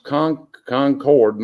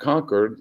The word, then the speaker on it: Concord: That is pronounced Concord, not Concord, like they say it in Massachusetts up north, which is a small cotton mill town very near Charlotte, North Carolina.